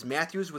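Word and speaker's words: Mathews: Next, my caching name is Matthews with one T, because people usually spell it the common way, Matthews with two T's.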